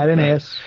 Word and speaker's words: Adonais: Who Mourns for Adonais or Adonai, depending Adonais. – On your pronunciation. – Yeah, Adonais. Yeah.